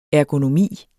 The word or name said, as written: ergonomi